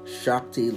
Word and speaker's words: Shakti: Shakti, let's like you spell it, S-H-A-K-T-I, Shakti Gwain. And I believe it was G-A-W-A-I-N.